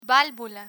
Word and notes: valvula: Spanish

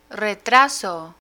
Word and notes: retraso: spanish

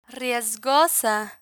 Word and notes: riesgosa: spanish

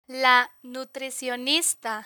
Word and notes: la nutricionista: Spanish

